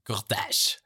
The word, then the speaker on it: courtage: Courtage. Jag vet inte hur man säger det. – Men, nej, det var ett perfekt uttal.